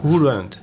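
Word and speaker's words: Whodunit: Aber wie spricht man es richtig aus? – Who done it?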